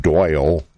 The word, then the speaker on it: Doyle: You can check them out at Doyle King on Facebook. My first name is spelled D-O-Y-L-E, Doyle King, on Facebook.